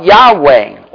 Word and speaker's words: Yahweh: It is spelled, from the Hebrew standpoint, YHWH. So you insert vowels from one of the other names of God, and it comes out Yahweh.